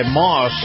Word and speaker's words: Maus: In this portion of the program, a conversation with Chris Markowski, brought to you by Moss Acura. Moss is spelled M-A-U-S.